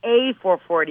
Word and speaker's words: EH440: That's pronounced A440 because Canadians say A.